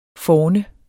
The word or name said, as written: forne